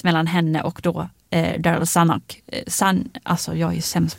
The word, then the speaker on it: Zanuck: Zanuck. Ja. – Zanuck.